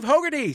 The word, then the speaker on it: Hogarty: Also playing for Team UK from the Regular Features podcast, it's Steve Hogarty.